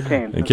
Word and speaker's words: Keynes: You got it right there, Keynes. – Keynes, yeah.